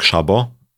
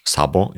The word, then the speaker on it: Szabo: Trzecią osobą, która jest podejrzana y, jest pan Nick Szabo, lub też sabo, jak mówią o nim Amerykanie, ale to myślę, że dlatego, że nie wiedzą, że szy czyta się jak szy.